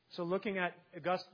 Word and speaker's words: Augustine: Augustine, whom I referred to at the beginning, using the proper pronunciation, he actually lived a life.